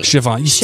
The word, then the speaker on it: Siobhan: Siobhan. Am I saying that right? – Siobhan. This is like 2 years in a row. You still can't get it right.